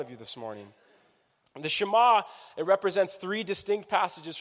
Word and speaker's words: Shema: And it's this thing called Shema. Say Shema. Shema. One more time. Say Shema. Shema. Say it like you speak Hebrew, okay? Like you're fluent. Shema.